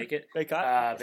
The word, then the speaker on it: Bacot: Armando Bacot. Is that how you say it? Carolina? – Yeah, sure. – Bacot. – Bacot.